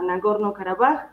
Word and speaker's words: Nagorno-Karabaj: Estamos hablando de esta región que se llama Nagorno-Karabaj, bien pronunciado, eh, Alto Karabaj también.